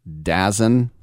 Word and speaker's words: DAZN: Actually, there's a TV network, streaming service, what have you, that does a lot of, I think, combat sports called DAZN, and it's spelled D-A-Z-N. Dazen.